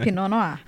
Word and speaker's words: Pinot Noir: Então, assim, pra gente começar do básico, o que, que você pode falar assim pra gente começar a entender a Pinot Noir? – Já tô feliz que você acertou a pronúncia, gente. Não é Pinot Noir, tá? Boa, já começou então, bem, né? Pinot Noir.